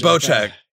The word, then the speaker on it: Bocek: I'm used to say Bojack. – Bocek.